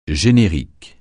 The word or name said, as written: générique